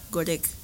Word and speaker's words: Goreng: No sé si se pronuncia Joren o Joren. – ¿Goreng? – Goreng.